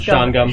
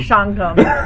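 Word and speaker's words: Shawangunk: I'll move it, only with the one correction that's pronounced Schwangum. – Schwangum. Thank you.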